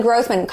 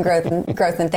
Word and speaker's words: Grothman: Glenn Grothman, Congressman, you corrected me on that pronunciation last summer, and I haven't forgotten, but I did for a split second. Congressman Grothman, thank you for being here.